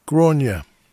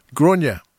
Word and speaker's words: Gráinne: And we started the set off with a surprise, a brand new release from, I've looked up how to say this, Gráinne. Gráinne Duffy comes from Ireland, and her name is spelt G-R-A-I-N-N-E. A bit like Siobhan, doesn't sound how you read it.